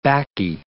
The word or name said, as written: Bacchae